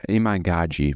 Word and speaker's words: Iman Gadzi: And it was basically a fan page talking about Iman Gadzi and, or Iman Gadzi, my bad. I realized that I was pronouncing it wrong for a long time,